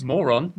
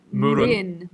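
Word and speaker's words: Mörön: Moron. – It's actually called Murun. – We can't pronounce it correctly. – But it's actually, like, spelt Moron.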